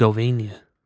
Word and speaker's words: Herzegovina: How do you say that? Herzegovina. – Wow, look at you. – Yeah, I roll my R's.